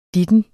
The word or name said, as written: ditten